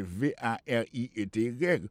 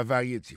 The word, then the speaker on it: variety: En anglais, il préécrit presque même genre, c'est V A R I E T Y. Ou t'ap kouri a di variété. C'est pas variété, prononciation c'est variety.